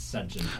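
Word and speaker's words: Sufjan: And then how do you pronounce that guy? – It's Sujan.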